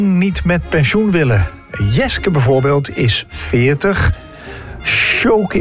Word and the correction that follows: Skjoni: is, hoe spreek je dat uit, zeg? Shkoni, 31. Dat is heel moeilijk. S-K-J-O-N-I.